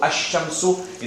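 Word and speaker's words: Ash-Shamsu (الشمس): Ash-Shamsu. You're not pronouncing the lam. You're joining directly with that letter.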